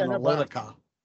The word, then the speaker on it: Analitica: Um, uh, apologies for mispronouncing it. – Ops oh, yeah, Analitica. um, and that's the Portuguese spelling of analytics. That's why it's weird.